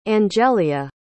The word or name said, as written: angelia